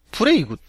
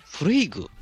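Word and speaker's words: Plague: プレイグって読むみたいですね、これね。 – プレイグ。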